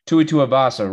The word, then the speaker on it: Tuivasa: He's the favorite in this one at minus 190 going against Tua Tuivasa, right? Plus 160. I'm pronouncing that correct? – Awesome, yes.